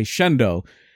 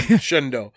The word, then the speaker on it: Shenandoah: And Muff Lawler was the Shenandoah, or as they would say, Shendo. – That's how they say it up here. Yeah. Shendo.